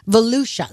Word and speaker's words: Volusia: Police in, I'm not sure how to pronounce this, Volusia County, Florida? Volusia, that's it.